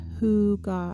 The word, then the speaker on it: hygge: Have you ever heard of HYGGA, spelled HYGGE? It is a Danish concept and is pronounced as HYGGA.